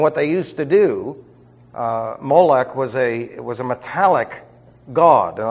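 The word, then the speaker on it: Melech: That's M-E-L-E-C-H. Molech, or Molech, is the way it's... I'm giving you the Hebrew pronunciation with a ch on the end.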